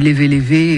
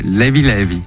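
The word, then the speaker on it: Léve Léve: DJ Tombé est notre invité pour la sortie du volume 2 de ses compilations sur Sao Tomé et Principe, qui s'appelle, moi je dis Levé Levé, et ça ne se prononce bien. Pas comme ça. Comment ils disent là-bas ? – Levé Levé. – Levé Levé.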